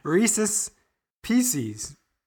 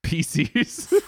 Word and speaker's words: Reese's Pieces: It is interesting why this is so hard to say, because it does, it rhymes when you, it still rhymes correctly. – Reese's Pieces. – Reese's Pieces, but everyone's like, nah, that's hard. It's Pisces.